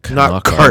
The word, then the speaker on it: Knockaert: They were very, on NBC at least, they were very deliberate about pronouncing the a hard K on Knockaert.